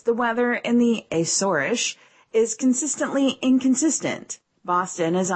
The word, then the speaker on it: Azores: Most European airlines only fly to the Azores, actually pronounced Azorish, in the summer months, despite the fact that the weather hovers around 60 degrees Fahrenheit all year.